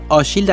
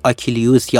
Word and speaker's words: آشیل: همین اول بسم الله آغاز کار بگم که آشیل در حقیقت تلفظ فرانسوی اسم پهلوان یونانی هستش که در زبان یونانی آکیلیوس یا آخیلیوس تلفظ می شده.